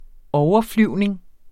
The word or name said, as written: overflyvning